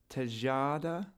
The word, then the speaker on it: Tejada: Luis Tejada. – Yeah, that's right. – Did I pronounce that right? – I think you did.